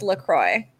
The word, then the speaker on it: LaCroix: No, the company actually came forward and said it's pronounced LaCroix.